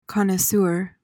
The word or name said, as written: connoisseur